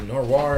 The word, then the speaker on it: noir: It is the urban fantasy, and it does get into the noir. Is that the right way to say it? – Noir. Sure. – Noir.